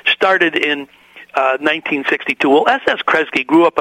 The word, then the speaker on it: Kresge: And let me get his name right. Is it Kresge? Krage? – S. Kresge. – Kresge. Okay, it looks like Kresge, doesn't it?